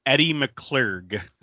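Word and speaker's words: Edie McClurg: Eddie McClurg, if I'm saying that right.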